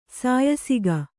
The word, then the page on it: ಸಾಯಸಿಗ: sāyasiga